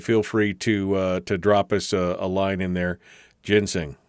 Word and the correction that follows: ginseng: What did I say? Ginsing? Yeah, ginseng. Sorry. My bad. Not enough coffee this morning. It's pronounced ginseng.